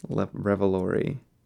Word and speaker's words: Revolori: Revolori? – Revolori.